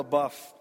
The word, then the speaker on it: LaBeouf: Shia LaBeouf, I checked that. He pronounces his name LaBeouf. People used to, never mind, that's not important, but it's Shia LaBeouf.